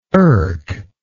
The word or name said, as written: erk